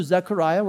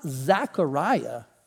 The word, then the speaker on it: Zechariah: Maybe you pronounce it with an A and you don't realize it. It's not Zechariah. It's actually Zechariah, Z-E. But I think I say it like a good old Southern boy, don't I?